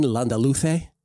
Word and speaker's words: Landaluce: On the men's side today, the big dog, Martin Landaluce, with the Castellano pronunciation.